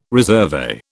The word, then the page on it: resurvey: British English